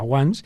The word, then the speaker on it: Once: Ajá, para el que no pille del todo el inglés, pues es once, lo escribimos como la once, pero en inglés se pronuncia once, porque es una película irlandesa.